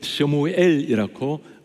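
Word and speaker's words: 쇼무엘이라고 (שמואל): 히브리어 말로 사무엘을 쇼무엘이라고 발음을 합니다.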